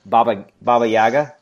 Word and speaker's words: Baba Yaga: And Baba Yaga.